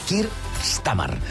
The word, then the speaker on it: Keir Starmer: Keir Starmer, que sembla que no sàpiga pronunciar jo aquestes hores del matí. Keir Starmer.